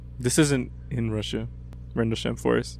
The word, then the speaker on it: Rendlesham: It's I brought, well, not me, but we brought Rendlesham Forest incident. – How do you say that? Rendlesham? – Rendlesham, right? – Yeah, like the Rendlesham incident.